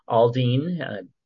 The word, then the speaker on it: Aldean: Aldean, probably pronouncing that wrong, was no, you're right. – What's that? – You're right.